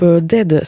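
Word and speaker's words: Dehd: On vient d'entendre à l'instant le groupe Dead, ça s'écrit D-E-H-D, e et c'est un trio de Chicago.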